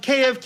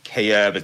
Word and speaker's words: Kyiv: Um, Kiev, Kiev again. It's it's it's it's like Kiev was the spelling um, that was pushed by Moscow. Kyiv is the pronunciation in Ukrainian, the spelling in Ukrainian.